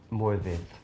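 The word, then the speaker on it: Morfydd: Well, I just did a film with Moivid, pronounced correctly, wow.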